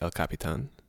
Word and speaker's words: El Capitan: Everyone's going to say it as El Capitan. – Okay. – Even though it should be El Capitan.